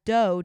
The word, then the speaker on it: Do: They also pronounced dough. Like Do Re Mi, right. – Like that was his name, as Do to reflect Applewhite's nickname, like do it.